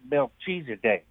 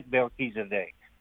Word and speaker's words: Melchizedek: My question is about Melchizedek. Is that how you say it? – It's close. We've heard it pronounced several ways, but that's good.